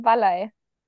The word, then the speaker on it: Valley: Wir sind in Oberbayern, Wallei. Wallei? Ein äh, Gmoor, eine äh, Gemeinde in der Nähe von Holzkirchen. Und deswegen wird das Wallei ausgesprochen.